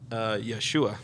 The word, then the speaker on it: Yeshua: Yeshua. Because I was saying Yeshua, and the locals corrected me. It's Yeshua. – Oh, yeah. That's cool. – I put the emphasis on the wrong syllable.